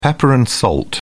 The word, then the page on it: pepper-and-salt: British English